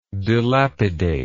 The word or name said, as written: dilapidator